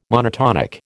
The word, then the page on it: monotonic: American English